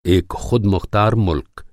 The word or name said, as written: ख़ुदमुख़्तार